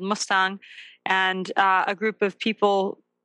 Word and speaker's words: Mustang: It's called, it's pronounced Mustang, but it's spelled like the car, Mustang.